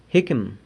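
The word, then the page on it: jicqum: San Lucas Quiaviní Zapotec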